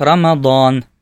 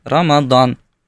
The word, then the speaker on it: Рамадан: В арабском языке произносится как Рамадан. Прям с таким твердым. Да, Рамадан. У персов оно произносится как Рамазан. Вот эту букву они произносят как букву З.